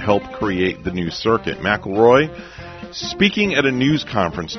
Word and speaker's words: McIlroy: And Rory McIlroy, did I say that right? – McIlroy, close enough.